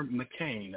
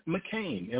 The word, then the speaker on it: McCain: Meyer McCain. That's spelled T-I-E-M-E-Y-E-R. T. Meyer McCain, M-C-C-A-I-N.